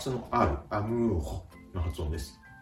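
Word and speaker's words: amour: R の発音は、うがいをするような、という発音に、ハヒフヘコの、この場合では、ふを添えるような形で、アムーホ、アムーホと言ってあげると、うまく発音ができます。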